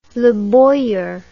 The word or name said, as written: Leboyer